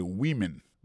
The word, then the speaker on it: women: Mais tandis que les mots femme dans au pluriel, qu'on a pas prononcé woman, donc c'est women, many women in the top job, ça veut dire dans gros position.